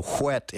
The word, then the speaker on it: whet: Now, the saw-whet owl, because of that sound, the whet in wet there is like whetting a knife or whetting a saw. Actually, W-H-E-T is how that's spelled, and it has to do with the sound of the owl.